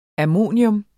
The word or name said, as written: ammonium